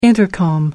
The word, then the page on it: intercom: American English